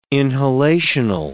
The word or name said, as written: inhalational